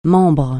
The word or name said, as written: membre